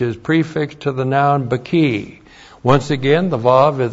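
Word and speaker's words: baki (בְּכִי): B-Shava K long I, baki.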